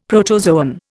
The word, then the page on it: protozoan: Indian